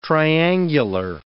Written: triangular

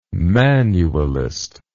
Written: manualist

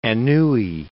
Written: Anouilh

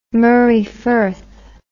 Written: Moray Firth

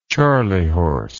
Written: charley horse

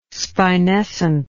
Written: spinescent

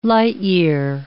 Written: light-year

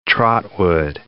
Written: Trotwood

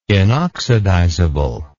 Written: inoxidizable

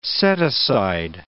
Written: set-aside

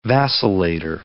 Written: vacillator

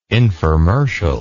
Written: informercial

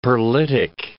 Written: perlitic

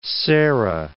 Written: serra

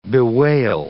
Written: bewail

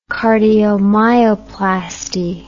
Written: cardiomyoplasty

